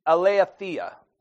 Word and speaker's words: aletheia: And the word is aletheia. Aletheia is the way it's pronounced.